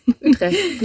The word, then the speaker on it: Utrecht: Also Utrecht wird gar nicht mit T hinten gesprochen. – Utrecht wahrscheinlich. – Ja, Utrecht.